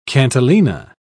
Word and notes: cantilena: British English

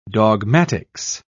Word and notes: dogmatics: British English

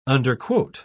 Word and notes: underquote: British English

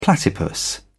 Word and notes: platypus: British English

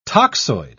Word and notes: toxoid: British English

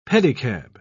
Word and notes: pedicab: British English

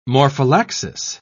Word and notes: morphallaxis: British English

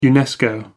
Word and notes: unesco: American English

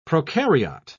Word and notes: prokaryote: British English

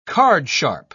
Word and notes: cardsharp: British English

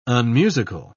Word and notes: unmusical: British English

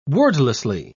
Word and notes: wordlessly: British English

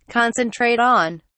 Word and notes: concentrate on: British English

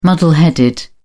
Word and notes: muddle headed: British English

